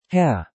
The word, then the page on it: Herr: German